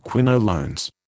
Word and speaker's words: quinolones: Ciflox is an antibiotic belonging to the group of medicines called quinolones, pronounced quinolones.